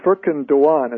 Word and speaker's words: Furkan Doğan: Please correct my pronunciation of this poor young dead person. – It is Furkan Doğan, as I understand it, in terms of the Turkish pronunciation. I mean, he's, of course, of Turkish descent. As an American, probably Dogan is just fine.